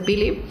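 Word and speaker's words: Peele: Pili, asumo que su, su apellido se pronuncia Pili.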